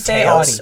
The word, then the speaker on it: Taos: I gotta say this right because I say Taos, but apparently it's Taos. – It's like how you say Audi.